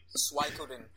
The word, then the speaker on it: Suikoden: But a character that has, you know, 100 different playable characters, like, you know, Suikoden or one of those kind of games. Am I even pronouncing that correctly? One of those games. – Suikoden, actually.